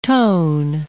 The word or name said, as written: tone